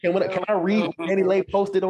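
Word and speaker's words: DaniLeigh: Danny Lay. How do you pronounce that? Oh, Danny Lay.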